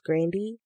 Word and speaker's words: Grande: If you Google it, she does an interview where she actually tells people the correct way to say her name, which is Grandy.